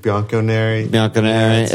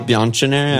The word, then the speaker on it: Bianconeri: Bianconeri. – Is it not Bianconeri? – No. CH is hard. Like a hard C.